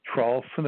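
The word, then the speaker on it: Traulsen: But if you simply put my name on the internet, it's Hugh, H-U-G-H, the last name Trawlson is spelled T like Tom, R-A-U-L-S-E-N like Nancy, and then Twitter after my name, you'll have everything right at the top.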